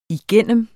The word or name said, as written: igennem